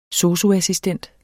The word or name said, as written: SOSU-assistent